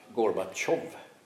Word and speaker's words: Gorbatjov: Och Gorbatjov stavas likadant. Ett E med två prickar över. Så att det är därför vi säger Tjov och inte Tjev.